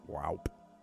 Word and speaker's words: Waup: W-A-U-P Tiger. Wow-P. Wow-P Tiger.